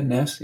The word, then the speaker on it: Naess: I see Martha Nussbaum here, and Arne Naess. I'm not sure if I'm pronouncing his surname correctly. – That's right. Yeah, Naess. Yeah.